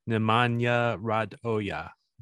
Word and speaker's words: Nemanja Radoja: The pronunciation in the announcement is Nemanja Radoja.